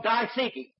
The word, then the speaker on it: diatheke: The word here for covenant is a word called diatheke, D-I-A-T-H-E-K-E, long E.